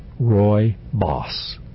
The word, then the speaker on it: rooibos: It is pronounced rooibos, R-O-Y-B-O-S-S, but it's spelled R-O-O-I-B-O-S.